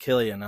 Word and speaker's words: Cillian: It's pronounced Cillian. I looked it up.